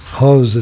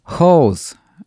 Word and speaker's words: Hauz: Hose, it's like H-A-U-Z, like that's phonetically how it looks. – Hose, H-O-U-Z.